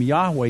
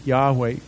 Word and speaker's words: Yahweh: That's the best pronunciation we can come up with. You know, we sang that song this morning, and there was that hallelujah that we sang, didn't we? That Yah is the first syllable of Yahweh. That's why we know that at least that's pronounced.